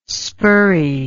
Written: spurrey

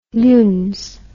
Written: luns

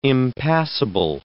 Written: impassable

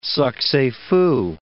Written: succes fou

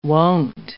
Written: won't

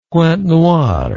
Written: pointe-noire

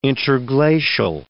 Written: interglacial

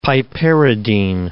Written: piperidine

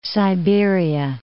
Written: Siberia

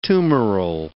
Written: tumoral

